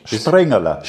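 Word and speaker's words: Springerle: das ich immer falsch ausspreche, aber Springerla, glaube ich, ist jetzt, gell? Das war schon nicht schlecht. Springerla. Springerla, es kommt auf die Betonung an.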